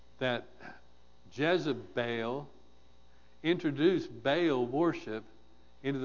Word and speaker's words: Jezebel: Stretch out that last syllable a little bit. Jezebel.